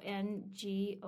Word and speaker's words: Ngozi: Ngozi, so Ngozi. – I think that's her middle name, which is Ngozi.